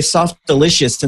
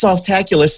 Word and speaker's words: Softaculous: I used to say soft delicious, and then I'd get, I don't know where I came up with that, but it's Softaculous. That's that.